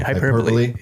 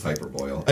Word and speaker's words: hyperbole: Hyperbole. – Yeah. – It's hyperboil.